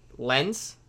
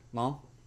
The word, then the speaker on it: Lens: Lens, is that right? Long. I think it's long. Long. Okay. Okay. Long.